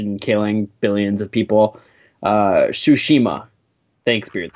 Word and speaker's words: Tsushima: Is that how it's pronounced? Tsushima? – Tsushima. – Tsushima. – Tsushima. – Tsushima. – Tsushima. – Tsushima. – Yeah, close enough.